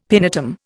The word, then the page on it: pinetum: Indian